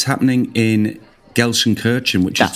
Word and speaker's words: Gelsenkirchen: I'm saying Gelsenkirchen.